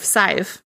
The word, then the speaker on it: Sadhbh: So he fled back home to Ireland and explained his colossal fuck up to his wife, Saif, which, by the way, is spelled S-A-D-H-B-H.